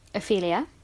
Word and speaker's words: Ophelia: Am I saying that right? – Ophelia.